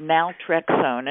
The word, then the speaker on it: Naltrexone: Okay, I can pronounce it, Naltrexone, and it took a while for me to learn.